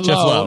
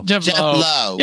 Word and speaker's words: Lowe: Low or Lau? Lau? – Jeff Lowe. – Jeff Lowe.